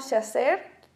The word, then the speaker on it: Shazer: Pues estoy en Instagram como Chaser guión bajo Marín. Mi nombre es, es, un poco diferente, entonces se escribe S-H-A-Z-E-R.